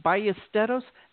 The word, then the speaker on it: Ballesteros: And folks, with my last name, Ballesteros, they don't even know how to say my last name.